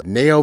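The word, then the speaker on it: Ngaio: The one other detective I'll mention very briefly is Nao Marsh. I had to look up the pronunciation. I found a site in which it explained it. It's N-G-A-I-O, Marsh.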